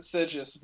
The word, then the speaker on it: Sigis: Now, a very important character that you created for this, and I'm not sure, is there a long, detailed backstory for Alex Sigis? – Did I pronounce that right? Yeah, I pronounce it Sigis, but I'm not sure.